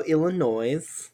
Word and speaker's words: Illinois: It is going to be a silent at the S. Because Illinois is how you say it.